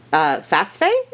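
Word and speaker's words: Pasiphae: So her name was, I'm probably going to butcher this, but you can correct me. Phasphae? Is that right? – Oh, yeah. I believe you say it correctly.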